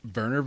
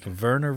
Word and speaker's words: Wernher: Okay, his entire name is Wernher, which Wernher is spelled W-E-R-N-H-E-R. – Wernher? – It's, yeah, but they like... – Yeah, I get it. – They rough up that W.